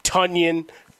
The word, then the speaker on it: Tonyan: Tonyan, remember it rhymes with Funyuns.